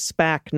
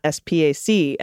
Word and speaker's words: SPAC: A SPAC, special purpose acquisition company, is referred to as SPAC, not SPAC. And I was calling it an SPAC, Dana.